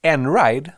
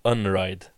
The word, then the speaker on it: Einride: Enride, som jag nu har fått lära mig att uttalas, men det stavas Einride. – Det finns också de som kallar dem Unride.